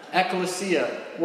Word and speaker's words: ekklesia: And yes, I know how to pronounce it because I asked a real scholar, Pastor Mark Morris. ekklesia.